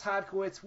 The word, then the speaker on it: Hodkiewicz: It's Wes Hodkiewicz.